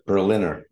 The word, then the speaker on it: Berliner: Neither. Berliner, like the city Berlin.